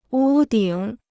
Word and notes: 屋顶: wū dǐng

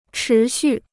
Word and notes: 持续: chí xù

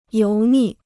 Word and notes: 油腻: yóu nì

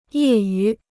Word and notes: 业余: yè yú

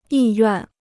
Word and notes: 意愿: yì yuàn

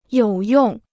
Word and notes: 有用: yǒu yòng

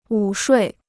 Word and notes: 午睡: wǔ shuì

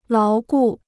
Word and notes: 牢固: láo gù

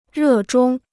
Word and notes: 热衷: rè zhōng

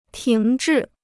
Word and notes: 停滞: tíng zhì

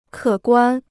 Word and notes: 可观: kě guān

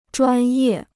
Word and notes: 专业: zhuān yè

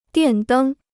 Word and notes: 电灯: diàn dēng

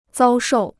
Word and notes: 遭受: zāo shòu

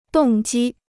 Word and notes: 动机: dòng jī

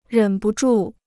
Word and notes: 忍不住: rěn bu zhù

